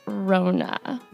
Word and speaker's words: Rhona: So this month's puppet was submitted by our listener, Avalon, and the puppet's name is Rona. – Rona. – But it's spelled R-H-O-N-A, and that was like a very specific thing that needed to be pointed out.